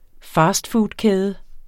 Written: fastfoodkæde

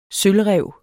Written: sølvræv

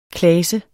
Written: klase

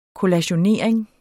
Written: kollationering

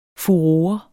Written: furore

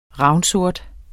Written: ravnsort

